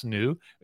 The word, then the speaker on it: NXU: So you went to Mesa, Arizona to visit, to try out a new fast charger prototype created by the team at NU, that's spelt NXU and it's pronounced NU.